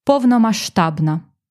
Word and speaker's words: повномасштабна: Або якщо швидко говорити, то ми не вимовляємо букву «с». Ми говоримо «повномасштабна».